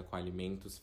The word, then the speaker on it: Qualimentos: Qualimentos a gente escreve bem é, como se fala mesmo, então QU Alimentos, tudo junto com Alimentos e o JR de Júnior.